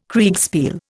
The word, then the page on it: Kriegspiel: Indian